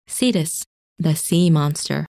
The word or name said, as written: Cetus